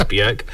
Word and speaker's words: Björk: And I did check for the pronunciation just to make sure I got it right. It is Björk.